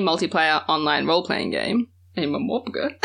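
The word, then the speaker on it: MMORPG: Is that the accepted pronunciation of that? – Yeah. – Okay, just... – Oh, really? – Yeah. Momopaga is, like, a real thing that people say.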